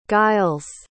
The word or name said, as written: guiles